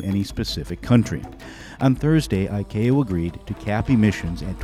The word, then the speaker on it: ICAO: They go by the initials ICAO, pronounced ICAO, which is part of the United Nations that deals with air traffic.